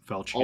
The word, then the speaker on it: Falchion: And the Falchion, I believe is how it's pronounced. – Falchion. – Also, Falchion. Yeah, Falchion.